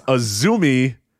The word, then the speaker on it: AZM: It's Azumi. AZM, of course. It's spelled AZM, but it's a Zumi. Don't ask.